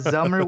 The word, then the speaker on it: Zummer: it is Zummer with a Z. I'm assuming Summer, but no, they're saying Zummer, just clarifying so no one thinks I'm reading it wrong.